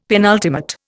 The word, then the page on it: penultimate: Indian